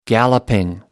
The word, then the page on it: galloping: American English